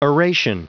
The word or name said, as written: oration